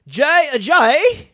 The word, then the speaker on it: Ajayi: Well, he's actually British, so you'd pronounce it Ajayi.